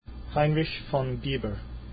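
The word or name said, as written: Heinrich von Biber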